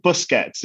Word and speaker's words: Busquets: Did I pronounce Busquets correctly? I feel like I'm having difficulties. – I think the popular pronunciation, which is not to say yours is wrong, just an alternative. I think Busquets is what people go for generally.